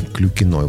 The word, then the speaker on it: Клюкина: Автор программы будет беседовать с Ольгой Клюкиной. Вот именно так ударение ставится.